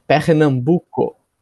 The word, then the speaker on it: Pernambuco: But I'd like to get started with just talking a little bit about where you're based and maybe where you're from, which is Penombro? – Pernambuco. – Man, I'm striking out in terms of Portuguese pronunciation.